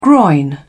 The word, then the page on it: groin: British English